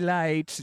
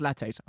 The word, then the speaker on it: lattes: We're drinking cafe lates. It's pronounced lattes. All right, lattes.